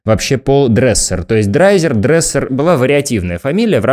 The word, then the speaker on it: Драйзер: А, дело в том, что драйзер – традиционное произношение, но а, и были разные варианты и в метриках, и в документах, и вообще в устном обиходе, в том числе варианты произношения фамилии. Кто-то называет его драйзером. В некоторых штатах США до сих пор говорят дризер, то есть скорее вот в таком ключе.